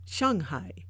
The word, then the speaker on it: Shanghai: Shanghai is the correct way to say Shanghai, which basically means oceanside.